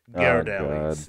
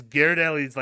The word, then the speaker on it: Ghirardelli: Ghirardelli's or Ghirardelli's. – Oh, yeah, thank you. It's Ghirardelli's, like, cocoa chocolate mixed into like a whole tub of mayonnaise.